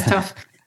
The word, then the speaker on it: Tough: So PSA, Tuff is my real name. It's pronounced Tuff in Scotland. But yeah, my name is Tuff.